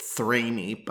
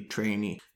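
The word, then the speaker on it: Threni: I always called it Thrainee, but I believe the Latin pronunciation would be Trainee.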